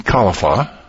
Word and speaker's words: Kalafa: We're going to be talking today to Amy Kalafa. Did I say that right? – Sounds good to me. Kalafa, yep. – Amy Kalafa, who made a movie called Two Angry Moms.